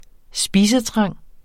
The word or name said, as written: spisetrang